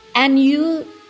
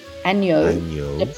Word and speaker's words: Agneau: Agneau. Don't pronounce the G. Agneau.